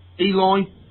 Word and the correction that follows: Eli: Or Ellie, sorry, the girl. – Ellie, that's right, yeah.